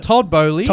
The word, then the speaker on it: Boehly: Todd Boehly.